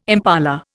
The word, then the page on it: impala: Indian